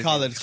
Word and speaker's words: Khalid: Or Khalid, how do you say Khalid.